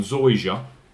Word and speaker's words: Zoysia: They're also playing on this Zoysia grass. I've got the pronunciation right on that one. Zoysia.